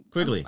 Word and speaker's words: Quigley: And she also Lynn Quinley. I can't even say her name. Lynn Quigley? – Sure. Quigley.